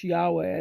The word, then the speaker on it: Yahweh: We pronounce in English Yahweh. I don't think that's exactly how it's really pronounced.